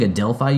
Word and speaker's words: Adelphi: I think Adelphi University is how that's pronounced.